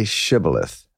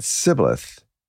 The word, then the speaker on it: Shibboleth: If he replied, "No," then they said, "All right, say Shibboleth, S-H-I-B, etc., Shibboleth." If he said Sibboleth, S-I-B, because he could not pronounce the word correctly, they seized him and killed him at the fjords of the Jordan.